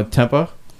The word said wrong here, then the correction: Tempe: Tempe. – Sorry.